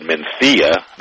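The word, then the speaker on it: Mencía: It's called Mencia, the TH sound, but it's spelled Mencia.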